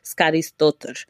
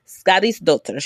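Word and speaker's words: Skadisdottir: Her name is Sage Skarisdottir. Skarisdottir.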